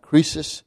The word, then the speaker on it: Croesus: Sardis was very famous for a king called Croesus. I call it, some others pronounce it Croesus. C-R-O-E-S-U-S. History records that he was very rich, and I think they kind of said that everything he touched turned to gold.